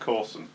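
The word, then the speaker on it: Corswain: Can I just point out it's pronounced Corson? – Is it Corson? – That's how you pronounce it. You know Boatswain? Bosun. – Bosun. Oh, so it should be Corson. – So Bosun is spelt BOATSWAIN. Bosun. – Oh, I learned a thing. – No. – Yeah. So his name is actually Corson. – Corson. – So Corson and Bosun.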